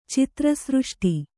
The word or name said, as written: ಚಿತ್ರಸೃಷ್ಟಿ